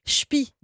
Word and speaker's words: Chépy: Libération nous emmène à Chépy dans la Somme, village picard de 1200 âmes, prononcé Chpi, d'ailleurs, en ch'ti.